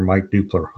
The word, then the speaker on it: Mike Dupler: I also have an author page on Facebook, which is Author Mike Dupler on Facebook. – And that's spelled D-U-P-L-E-R.